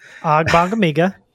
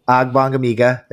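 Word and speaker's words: Ogbongbemiga: And you mentioned, I'm not even going to pronounce his last name, but Amen. – Agbongamiga. – Agbongamiga. There we go.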